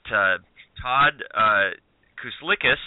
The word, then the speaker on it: Kuslikis: We've got Todd Kuslikis.